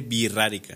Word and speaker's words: Wixárika: Sí, porque Wixárika, la X es R.